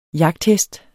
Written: jagthest

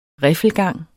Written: RIFFELGANGE

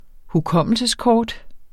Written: hukommelseskort

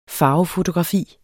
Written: FARVEFOTOGRAFI

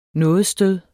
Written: nådestød

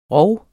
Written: rov

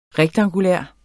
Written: rektangulær

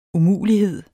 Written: umulighed

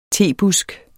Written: tebusk

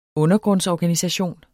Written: undergrundsorganisation

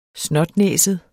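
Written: snotnæset

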